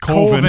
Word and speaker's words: Coven: And they're trying to make a horror movie called Coven, which is pronounced Colvin.